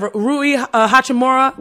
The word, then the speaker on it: Rui Hachimura: The Wizards, they're looking all right, because adding Russ, having Bradley Beal, you get the Denny Avdija from Israel, you have Rui Hachimura.